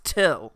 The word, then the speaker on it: Thgil: He says, "My name is Till, spelled T-H-G-I-L. Pronounced Till."